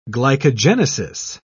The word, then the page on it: glycogenesis: British English